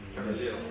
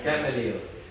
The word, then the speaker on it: Gamaliel: Gamaliel. – Gamaliel and you know, and then I lived like him.